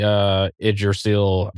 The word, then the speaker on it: Yggdrasil: I think the technical pronunciation is Yggdrasil, but yeah, anyway. Yeah, I had that down as we get to see Yggdrasil.